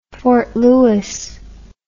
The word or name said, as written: fort lewis